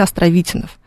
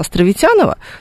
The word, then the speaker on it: Островитянов: Он на самом деле Островитянов. Но поскольку абсолютно все, кто видит это название, произносят Островитянова, то у нас произошла удивительная, удивительная биполярочка в этом смысле. Потому что академик остается Островитяновым, а вот улица официально в словарях Островитянова.